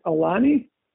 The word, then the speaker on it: Aulani: Aulani? – Yep.